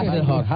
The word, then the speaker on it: Hasselhoff: Ese era Michael Hesselhoff. Ese era Hasselhoff.